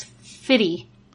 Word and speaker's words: FITE: F-I-T-E. – F-I-T-E. – Yeah. – Pretty sure that's Fitty. – Yeah, Fitty.